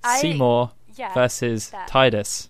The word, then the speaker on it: Titus: That is the official pronunciation. – Titus, like the tide.